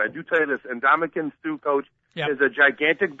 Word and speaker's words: Ndamukong Suh: Ndamukong Suh. Okay. Ndoumkongsuh.